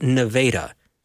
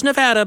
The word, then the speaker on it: Nevada: I spent a couple of years down in Iowa, and they have Nevada, spelled the same as Nevada, but it's pronounced Nevada.